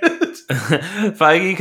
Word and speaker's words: Feige: Is it Feige? Is that how you say it? – Yes, Kevin Feige. – You pronounce the G? Feige? – Oh, yeah, sorry.